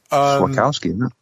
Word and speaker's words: Wachowski: Wachowski. – Wachowski. – Yeah, that's exactly what I said.